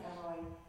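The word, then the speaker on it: El Roi: Elroi, some pronounce it Elroi.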